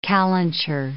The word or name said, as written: calenture